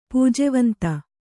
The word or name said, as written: ಪೂಜೆವಂತ